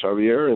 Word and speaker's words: Javier: You pronounce it well in Spanish, Javier, and in English, Xavier.